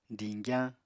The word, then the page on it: diñgyañ: apatani